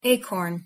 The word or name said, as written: acorn